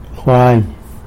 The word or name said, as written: Jwany